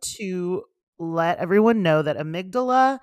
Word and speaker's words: amygdala: We just looked it up, how to pronounce it. Pip was correct. It's amygdala.